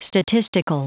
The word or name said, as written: statistical